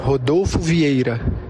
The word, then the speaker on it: Rodolfo Vieira: Rodolfo Vieira.